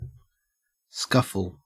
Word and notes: scuffle: Australian English

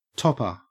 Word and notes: topper: Australian English

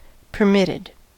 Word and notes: permitted: American English

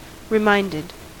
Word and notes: reminded: American English

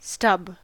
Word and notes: stub: American English

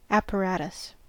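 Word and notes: apparatus: Canadian English; American English

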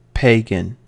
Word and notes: pagan: American English

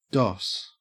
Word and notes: doss: Australian English

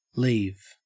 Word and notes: leave: Australian English

